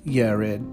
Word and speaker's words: Jared: I'm going to pause right there because in Hebrew there is no Y, so that J, I'm sorry, there is no J, so that would be a Y, so it would be Yared, but I'm going to pronounce things as it is for English so we can follow along.